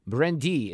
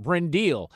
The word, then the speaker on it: Brindille: The restaurant's name is, it's spelled B-R-I-N-D-I-L-L-E. Brendi is the French name, but I'm going to call it Brendil just because we're from Missouri.